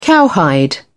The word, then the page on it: cowhide: British English